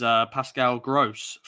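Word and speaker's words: Pascal Groß: And then the other midfielder I've gone is Pascal Gross from Brighton. Is that right? Have I said that right? – Yeah, you've done it right.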